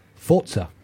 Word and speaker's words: Forza: Forza.